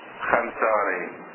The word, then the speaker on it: الخونساري: الخونساري طبعا هي بالفارس لماذا تقرأ خنساري؟ خنساري لماذا؟ قاعدة فرضية في الفارسية، الواو تلفظ لكن في موارد لا تلفظ الواو. ما هي هذه الموارد؟ يقولون الواو إذا جاء بعدها ألف وكان قبلها خاء تلفظها ألف، مثلا خواهر، خواهر يعني أخت، ما يلفظون خواهر يلفظون خاهر، كأن الواو معدومة.